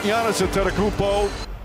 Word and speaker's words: Giannis Antetokounmpo: What a performance without Giannis Antetokounmpo.